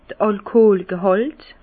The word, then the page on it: d'r Alkoolgehàlt: Bas Rhin; Herrlisheim